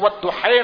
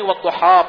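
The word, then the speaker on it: والضحى: اس میں اس کو والضحی نہیں والضحا پڑھا جاتا ہے.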